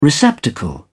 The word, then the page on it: receptacle: British English